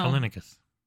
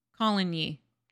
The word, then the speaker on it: Coligny: Callinicus. – Coligny.